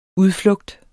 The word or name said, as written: udflugt